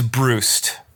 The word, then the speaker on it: Brust: Uh, I believe it's Brust.